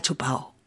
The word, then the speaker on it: chupado: normalmente lo decimos omitiendo la D en la pronunciación, o sea que normalmente oirás está chupao, está chupao.